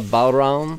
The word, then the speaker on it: bodhrán: Katie Card played the bodhrán.